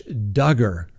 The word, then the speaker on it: Duggar: All right, next on the docket, Josh Duggar rhymes with hugger.